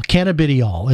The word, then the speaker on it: cannabidiol: So to kind of give you the little bit of a background on this, CBD, or what is officially known as cannabidiol,